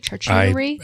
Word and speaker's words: charcuterie: And a little charcuterie board. Do I say that right? Charcuterie? – I am from Apopka.